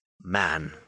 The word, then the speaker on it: Man: My name is Man.